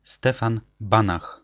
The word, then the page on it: Stefan Banach: ˈstɛfan ˈbanax; Polish